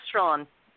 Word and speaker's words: Straughan: How do you pronounce it? Sorry. – Just Strahan. Scots always Strong. – Add extra letters, so.